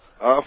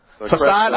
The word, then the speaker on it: facade: Is it facade or facade panels? – Facade. – The correct pronunciation. – Facade.